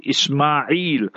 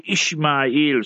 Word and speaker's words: Ismail: But we must pronounce it properly, Ismail. And in Hebrew, and then they say Ishmael.